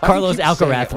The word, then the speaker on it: Carlos Alcaraz: Carlos Alcaraz, really like appreciate your pronunciation.